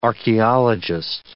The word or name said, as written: archaeologist